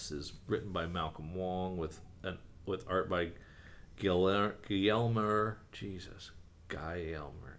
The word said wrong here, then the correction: Guillermo: I know how to pronounce that. Help me. – Guillermo. – Jeez. Guillermo Angel.